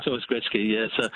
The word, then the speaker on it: Gretzky: Wayne Gretzky has always been pronounced Wayne Gretzky, and I suspect Michael just wasn't familiar with how to pronounce it when he read it off off.